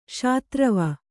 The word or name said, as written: ಶಾತ್ರವ